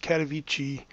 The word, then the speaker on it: Katowice: So, Mariah, over the last few weeks, there has been a UN sanctioned meeting on climate in a town which I think is properly pronounced Katowice, Poland.